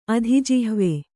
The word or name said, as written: ಅಧಿಜಿಹ್ವೆ